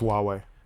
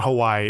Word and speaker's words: Huawei: It's Huawei. It's pronounced Huawei. – Is it? – Not Hawaii. It's Huawei.